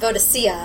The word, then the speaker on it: Boadicea: I'm going to say that she's pronounced Boadicea.